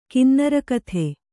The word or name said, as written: ಕಿನ್ನರಕಥೆ